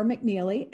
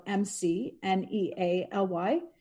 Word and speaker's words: McNealy: So my Twitter handle is Megan underscore McNealy, M E G A N. And then my last name is spelled M C N E A L Y. Instagram, it's at Megan McNealy.